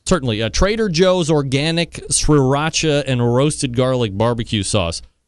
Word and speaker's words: sriracha: He says, sriracha. – Sriracha, like sriracha. Yeah. – Sriracha. So that first R is kind of silent.